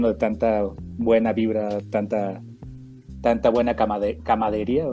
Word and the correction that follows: camaradería: o cómo se dice, Camaradería. – Camaradería, sí.